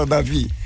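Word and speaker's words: David: É o Davi com D no fundo, no fim. Mas ele é mudo. Eu perguntava pra minha mãe, por que que botaram esse D no meu nome se fala Davi? – Aí falou, porque ele é mudo.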